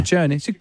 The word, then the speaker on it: Ciccone: Ciccone. What is it, sir? – Well, in Italian, it works the other way. So the double C's are hard C's and the singular C's are soft C's.